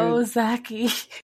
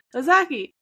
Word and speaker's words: Ozaukee: It's O, like, oh boy, za ki Ozaukee. Ozaukee. the intro there is a Ozaukee. – Jeez. Ozaukee. It's like when you see a cute key.